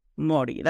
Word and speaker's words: Māori: Yeah, so it's like the way you pronounce it is, it's actually spelled M-A-U-R-I, but really the way you pronounce it is if you say the word moldy, and then you take out the L, Māori.